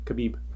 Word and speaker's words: Khabib: Khabib with a B. Khabib.